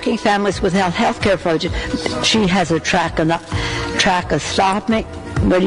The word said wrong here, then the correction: tracheostomy: How do you say this? Trachostomy? – Tracheostomy.